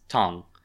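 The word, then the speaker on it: Tong (唐): So anything that contains that character you know is pronounced Tong.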